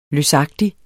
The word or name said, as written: løsagtig